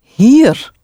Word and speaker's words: hier: The word for here is hier. Hier, spelled H-I-E-R.